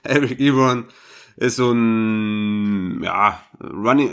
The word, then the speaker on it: Ebron: Wie wird er ausgesprochen? – Ja, Eric Ebron. – Ebron, okay, wir bleiben bei Ebron, korrigiert uns, wenn wir falsch liegen.